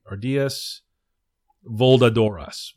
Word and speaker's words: Ardillas voladoras: So the flying squirrels become the Ardillas velocidores. Oh no, did I say that right? Ardillas voladoras, voladoras, Ardillas voladoras, which is just Spanish for flying squirrels.